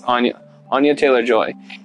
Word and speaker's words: Anya Taylor-Joy: Anna Taylor-Joy, our Anna, I want to make sure I pronounce it right, but I think it's Anya Taylor-Joy, is fantastic as the main character.